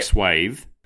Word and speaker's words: swath: The thing about trivia is that it's meant to cover such a broad swath of knowledge Is that like swathe? – Right, so after a quick Google, it turns out swath is how the Americans pronounce it.